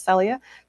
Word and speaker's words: Celia: So, um, probably the biggest gasp of the night was the reveal that Celia Rose Gooding, who, by the way, I mispronounced her name earlier, but her name is pronounced Celia.